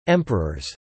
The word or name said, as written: emperors